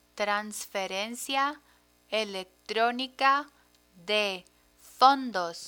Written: transferencia electronica de fondos

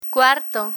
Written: cuarto

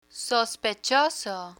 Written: sospechoso

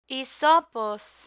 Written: hisopos

